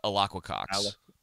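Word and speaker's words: Alaqua Cox: Alacqua Cox.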